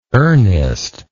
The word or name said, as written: ernest